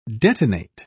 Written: detonate

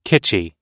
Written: kitschy

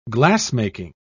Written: glassmaking

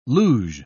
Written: luge

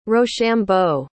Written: rochambeau

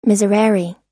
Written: miserere

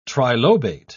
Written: trilobate

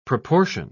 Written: proportion

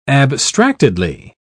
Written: abstractedly

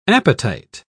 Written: appetite

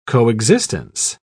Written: coexistence

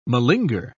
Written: malinger

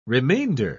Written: remainder

